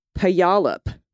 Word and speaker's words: Puyallup: Puyallup. Sounds like polyp.